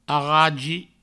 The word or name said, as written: arragi